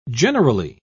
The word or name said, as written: generally